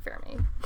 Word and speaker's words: Fermi: So how, you took an Italian class, how would you pronounce this word? – I would just pronounce it Fermi.